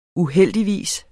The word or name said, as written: uheldigvis